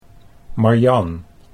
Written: Marjan

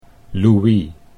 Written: Louis

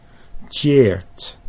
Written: Tjeerd

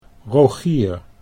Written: Rogier

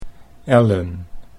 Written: Ellen

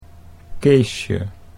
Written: Keesje

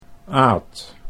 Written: Aad

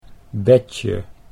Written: Betje

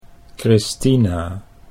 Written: Christina

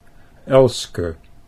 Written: Elske

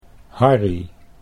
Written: Harry

